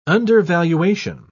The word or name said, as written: undervaluation